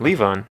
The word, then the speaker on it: Levon: Is it Levon? – I mean, I just say it like the Elton John song. – Okay, which Elton John song is that? – Levon.